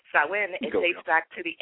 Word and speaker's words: Samhain: Before it was called Halloween, it was called Samhain.